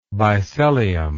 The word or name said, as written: bisellium